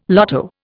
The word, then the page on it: lotto: Indian